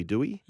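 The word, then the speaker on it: Doueihi: So the Lebanese brothers, if you like, Mitch Moses and Adam Dewey. – It's not Dwyer. – No, he was in the pronunciations, he wants to be Dewey.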